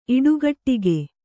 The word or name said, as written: ಇಡುಗಟ್ಟಿಗೆ